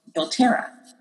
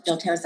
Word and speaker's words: doTERRA: doTERRA. Mm-hmm. It's spelled D-O-T-E-R-R-A. That's the company that I get my oils from and teach about and build a business with.